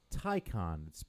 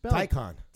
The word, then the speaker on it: Taycan: Okay, it's pronounced Taycan. – Taycan. – T-A-Y, C-A-N, but it's right. pronounced Taycan. – Now like this is Taibo, but Taycan.